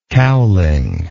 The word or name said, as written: cowling